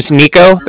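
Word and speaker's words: Niko: It's my name's Nico. That's N-I-K-O.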